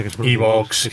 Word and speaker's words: iVoox: Pero bueno, en Spotify está, en iBooks, en iVoox, como me han dicho que se pronuncia. – iVoox, que iVoox es de posmodernos.